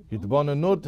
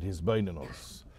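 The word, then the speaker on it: his bainanus (התבוננות): Hitbonenut is the noun, or I pronounce it his bainanus.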